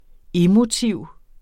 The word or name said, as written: emotiv